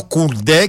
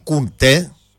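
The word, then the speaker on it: Koundé: είναι ο Κουντέ και όχι ο Κουντέ.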